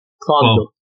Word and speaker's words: Clonmel: And correct me if I'm wrong, this is in the city of Clonmel? – Clonmel. Yes, Clonmel.